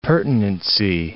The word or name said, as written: pertinency